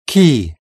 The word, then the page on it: key: British English